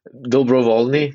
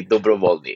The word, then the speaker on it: Dobrowolny: I grew up with people butchering in my whole life, so there's not really a correct way to say it, but I say Dobrowolny. It's Czech.